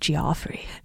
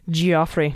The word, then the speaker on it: Geoffrey: Ooh, and it's spelled Geoffrey. Geoffrey. Jeff with a G.